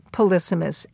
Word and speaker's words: polysemous: But it also can be and often is polysemous, and that is spelled P-O-L-Y for poly, many, and S-E-M-O-U-S. S-E-M, seem, comes from the Latin word for meaning, you know, like semaphore flags that have meaning.